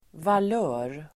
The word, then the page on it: valör: [val'ö:r]